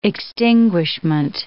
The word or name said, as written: extinguishment